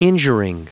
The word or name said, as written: injuring